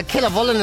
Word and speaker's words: Killavullen: Is it Killavullen? Would that be how you pronounce that, BJ? You being an adopted Corkonian? – Killavullen is what it looks like to me, yeah.